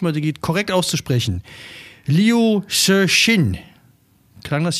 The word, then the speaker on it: Liu Cixin: Fiction writer Liu Cixin. Liu Cixin.